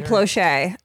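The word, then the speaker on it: Plauché: And then, do you know who Jody Plosh is? – No, that sounds like a fake name. – That's because it's Jody Ploche.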